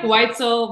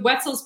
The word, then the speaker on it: Weitzel: It's like Weitzel. People say Weitzel all the time because of Weitzel's pretzels, which is correct.